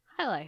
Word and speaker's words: Hialeah: They kind of, you kind of drop the A a little bit, like you kind of say Hialeah. – Oh, Hialeah.